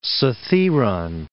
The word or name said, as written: Cithaeron